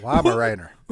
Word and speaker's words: Weimaraner: What is the correct pronunciation of them two words? – Weimaraner. A Weimaraner.